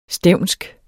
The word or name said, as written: stevnsk